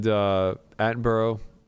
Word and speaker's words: Attenborough: And Attenborough. Am I pronouncing his last name right? – Yeah. – Okay, good.